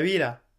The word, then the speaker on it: vida: Viva, viva la vida. Ça c'est ce que j'ai appris aussi. Les V se prononcent B en espagnol. La vida.